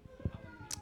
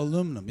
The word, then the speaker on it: aluminum: Aluminum was made in North America, which means we get to decide how it's fucking pronounced. You guys can say aluminium all you goddamn want. I'm right. In this alone. – You spell it wrong.